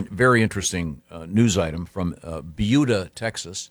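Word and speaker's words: Buda: And we were learning how to pronounce Beulah.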